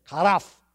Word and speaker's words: charaph: Thank you, Leah, for teaching me how to say it. Karaf. Karaf. It's spelled C-H-A-R-A-P-H. Karaf.